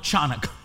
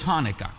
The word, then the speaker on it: Hanukkah: Well, it's called the Festival of Lights, or Hanukkah, which in the Hebrew, you may see it with a C at front of it, but do not call it Chanukah. Because it's not. The C is silent. It's Hanukkah no matter how you cut it, okay? So it's Hanukkah, all right?